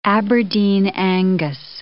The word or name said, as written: Aberdeen Angus